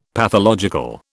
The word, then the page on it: pathological: British English